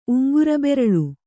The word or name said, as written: ಉಂಗುರಬೆರಳು